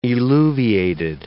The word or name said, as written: eluviated